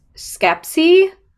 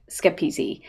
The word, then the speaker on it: Schepisi: This was released by Metro-Goldwyn-Mayer Films, directed by Fred Skepsy, I think is how you say it. I had to listen to like a couple of other people saying it out loud, but I think it's Skepsy. This